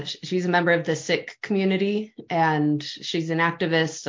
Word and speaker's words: Sikh: Some people call it Sikh, the Sikh religion, but got she it, it. – she pronounces it Sikh.